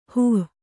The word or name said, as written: ಹೋ